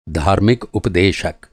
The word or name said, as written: उप